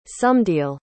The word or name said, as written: somedeal